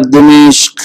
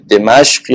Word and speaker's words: دمشق: چنان قهد سالی شدن در دمشق خیاران فراموش کردند عشق خب این تلفظ درستش با دو تا کسره یا کسره اول و فتح دومه دمشق یا دمشق هر دو درسته ولی دمشق غلط توی انگلیسی هم همینجوریه دیگه دمسکوس